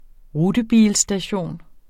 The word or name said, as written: rutebilstation